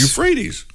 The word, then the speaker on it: Euphrates: Euphrates. – Euphrates? – Euphrates.